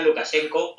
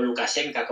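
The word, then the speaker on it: Lukashenka (Лукашэнка): Lukashenko o Lukashenka, como al parecer se pronuncia en bielorruso, que pues se aprende uno en una semana, pues ahí está en el cotarro.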